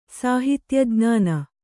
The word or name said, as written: ಸಾಹಿತ್ಯಜ್ಞಾನ